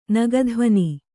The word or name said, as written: ನಗಧ್ವನಿ